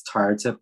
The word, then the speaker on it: Tharathip: Yeah, I think when it comes to pronouncing of names, like I know I have a lot of silent H's in my name because it's Tara Tip, but every T has a H behind it basically. And so if you were to pronounce it correctly by the way you read it, it would be Tara Tip, right?